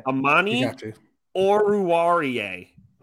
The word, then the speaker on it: Amani Oruwariye: I looked this up, how to pronounce this, so I'm going to do it right. – Okay. – Amani Oruwariye. – Oh, that is right. That's totally right.